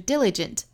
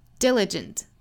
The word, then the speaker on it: diligent: 这个词它其实正确的读音呢应该是重读在第一个音节上的，应该是 diligent diligent diligent study is 1 day with a great teacher。